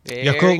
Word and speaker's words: Yakult: Yakult, like cool. The yogurt drink.